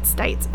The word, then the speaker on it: Stites: It later came out that Robert Stites, I think it's his name is, you pronounce it Stites, S-T-I-T-E-S.